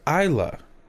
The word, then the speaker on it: Isla: Why is there an S in her first name if it's pronounced Isla? – Because it's not American. – I-S-L-A. Yeah, that's not American English.